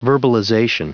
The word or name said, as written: verbalization